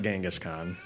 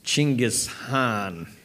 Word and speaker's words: Chinggis Khan: It's not Genghis Khan, it's Chinggis Khan.